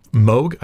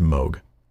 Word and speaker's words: Moog: Moog? I think it's pronounced Moog. Anyways, M-O-O-G, but I think it's pronounced Moog. Here's a clip of him, though.